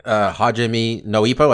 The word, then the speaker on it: Hajime no Ippo: When you started saying Hajime no Ippo, I say that right? – Yes.